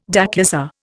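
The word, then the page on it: déclassé: Indian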